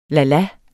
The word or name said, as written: lala